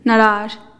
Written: narazh

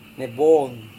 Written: nebwon

